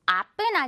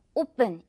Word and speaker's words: open: 아픈 아니고요. open 이렇게 너무 짧지도 않고 open 역시 굴곡 넘어가는 이 느낌, feel 살려주셔야 됩니다.